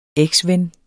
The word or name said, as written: eksven